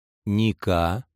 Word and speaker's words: ника: «Да здравствуют милосердные Венеты и Просины!» После состязаний объединившаяся толпа вышла из ипподрома, уговорившись о кодовом слове «побеждай», «ника», чтобы в их ряды не проникли солдаты. Сноска. Обычно интуитивно ставится ударение на первый слог. Важно подчеркнуть, что на самом деле оно падает на слог второй.